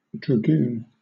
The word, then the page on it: dragoon: British English